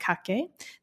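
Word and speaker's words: kakke: So in Japan, now we're talking about kake, the Japanese pronunciation of Fuzhi,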